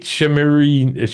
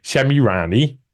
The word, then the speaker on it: Shemirani: The Weekend Truth Festival will feature speakers such as Katie Hopkins, Kate Shemirani, now I got that name wrong, Shemirani, there you go, I got that one right now, Dolores Cahill, Matt Letizia, never forget that name, Mark Atwood, David Clues, Allegedly Dave, Sovereign Pete, and many others.